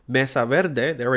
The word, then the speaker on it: Mesa Verde: Mesa Verde. Oh my god, I'm Spanish and I said that wrong. Mesa Verde, there we go.